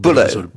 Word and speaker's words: Bullough: But how do you pronounce B-U-L-L-O-U-G-H? Anyway, that's his name. It sounded like a Buller. – Buller.